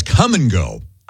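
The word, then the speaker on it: Kum & Go: Iowa also has Come and Go. – I know. Yeah, it's spelled in a weird way. – K-U-M. – It's really awkward. – Come and go.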